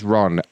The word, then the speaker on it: Ron: My name is Ron, R-O-N.